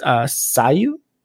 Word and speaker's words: Sayu: Really, the only reason I'm talking about this is because one of the new characters that just got announced, Sayu, is that how you say it? – Mm-hmm.